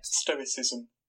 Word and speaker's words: stoicism: Stoicism.